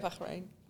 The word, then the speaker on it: Bahrain: Yeah, so I volunteered at one tournament when I was living in Bahrain for a while. And I love it, Bahrain.